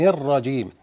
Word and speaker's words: الرجيم: نبهنا هذه في كل حلقات نرق الهمزة من كلمة أعوذ ومن بين الذال، وكذلك الطاء، أعوذ بالله من الشيطان الرجيم، ونكسر النون بينها كده، الرجيم. البعض يخطئ الرجيم أو يغنها الرجيم، أو يجيب ياء قبل الميم الرجيم، كل دي أخطاء.